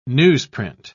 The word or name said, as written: newsprint